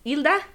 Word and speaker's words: Hilda: Well, my name is Hilda. If you want to pronounce it in Spanish, it's Hilda.